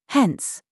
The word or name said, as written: HAHA